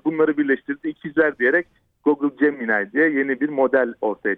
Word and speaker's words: Gemini: Acaba Google nasıl cevap verecek diye e, tahmin edilirken veya düşünülürken Google Gemini. Doğru mu telaffuz ediyorum? Öncelikle onu sorayım. Gemini, Gemini diye söyletiliyor.